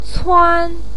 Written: cuan1

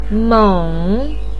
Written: meng3

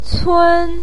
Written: cun1

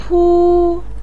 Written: pu1

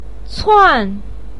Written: cuan4

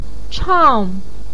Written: chang4

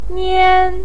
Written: nian1

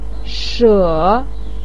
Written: she3

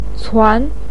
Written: cuan2

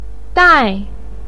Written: dai4

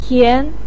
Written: tian2

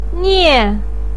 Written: nie4

